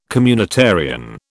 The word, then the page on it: communitarian: British English